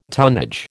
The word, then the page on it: tonnage: American English